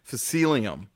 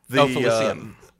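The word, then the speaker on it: felicium: I can't remember if I'm pronouncing this correctly, Phacelium. – Phacelium. – Phacelium, thank you. – Yes. – Much better pronunciation.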